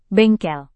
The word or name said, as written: bengkel